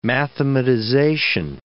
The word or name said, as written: mathematization